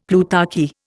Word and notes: plutarchy: Indian

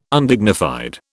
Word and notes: Undignified: British English

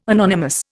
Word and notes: anonymous: Indian

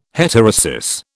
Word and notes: heterosis: British English

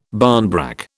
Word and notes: barnbrack: British English

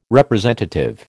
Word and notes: representative: American English

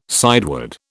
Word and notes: sideward: British English

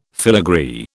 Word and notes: filagree: British English

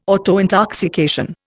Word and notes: autointoxication: Indian

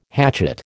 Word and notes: hatchet: American English

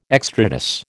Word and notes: extrados: American English

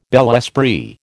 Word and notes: bel esprit: American English

